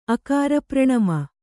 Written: ಅಕಾರಪ್ರಣಮ

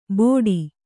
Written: ಬೋಡಿ

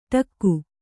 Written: ಢಕ್ಕೆ

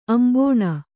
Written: ಅಂಬೋಣ

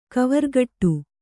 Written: ಕವರ್ಗಟ್ಟು

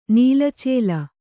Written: ನೀಲಚೇಲ

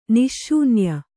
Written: ನಿಶ್ಶೂನ್ಯ

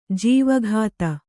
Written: ಜವಗಾತಿ

